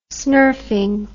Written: snurfing

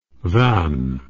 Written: Vannes

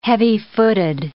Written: heavy-footed

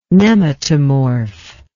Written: nematomorph

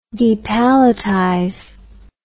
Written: depalletize

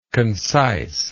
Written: concisely

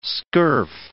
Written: scurf

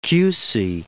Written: Chiusi

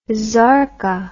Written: sarka

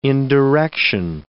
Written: indirection

